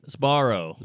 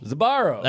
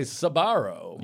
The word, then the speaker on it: Sbarro: One of those, it's like Sbarro, where it's just like weird consonants in front of each other. How do you say that? – Sbarro. – Sbarro. – That took me 8 years to learn. – Yeah. Some people say Sbarro. – Yeah. – But that's not right.